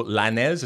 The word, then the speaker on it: Lainez: Matt Miazga, center back for the national team, making fun of the height, and I said the diminutive stature of the midfielder, Diego Lainez. Is that what we're calling him now? – Correct.